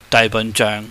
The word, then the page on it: daaihbahnjeuhng: cantonese